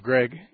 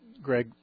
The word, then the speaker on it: Gregg: Steve Gregg is my name. Gregg is spelled G-R-E-G-G.